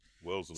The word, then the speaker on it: Wellesley: At Wesley College. – Wellesley. – Studied the black. – Wellesley College. Take that again.